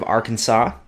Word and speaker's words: Arkansas: Going to the fine state of Arkansas.